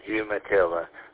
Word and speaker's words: Umatilla: And yeah, you said that really good, Umatilla, Florida.